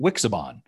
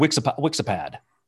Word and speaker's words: Wixiban: Tuvok asked Neelix whether and see, this is where Tuvok pronounces it Wixiban. Because before I heard Wixipad. And then now it's Wixiban. And I thought, that rhymes with Cinnabon, right?